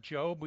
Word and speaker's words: Job: That's pronounced Job. We just do that to confuse you. It's the book of Job. I don't know why it's pronounced Job.